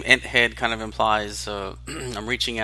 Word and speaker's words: Enthead: Like, Enthead.